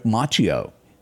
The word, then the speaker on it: Macchio: And Ralph, and am I saying his name right? Ralph Macchio. – Macchio.